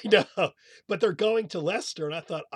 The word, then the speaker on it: Leicester: It's spelled, it looks like it's spelled Leicester, L-E-I-C-E-S-T-E-R. – I think it's Lester. – Right, it is.